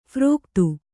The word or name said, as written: ಫಾರೀಕತ್ತು